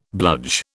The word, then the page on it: bludge: British English